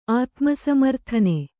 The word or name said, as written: ಆತ್ಮಸಮರ್ಥನ